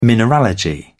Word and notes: mineralogy: British English

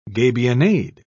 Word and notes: gabionade: British English

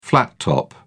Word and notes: flattop: British English

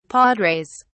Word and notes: padres: British English

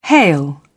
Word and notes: hail: British English

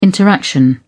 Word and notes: interaction: British English

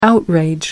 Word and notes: outrage: American English

